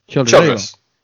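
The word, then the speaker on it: Childres: Isaac Childress or Childress or how do you say it? – Childress.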